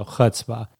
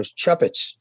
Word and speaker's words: chutzpah: I love that you chose one of the hardest words for Gentiles to pronounce also, chutzpah. – Chutzpah. Yeah, I know. I did a podcast and I go, chuppets. I didn't even bother telling him because I knew it was impossible.